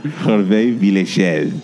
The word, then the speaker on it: Hervé Villechaize: Hervé Villechaize.